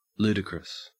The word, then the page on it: ludicrous: Australian English